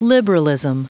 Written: liberalism

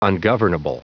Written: ungovernable

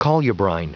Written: colubrine

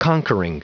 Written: conquering

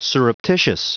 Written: surreptitious

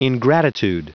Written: ingratitude

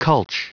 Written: culch

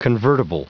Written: convertible

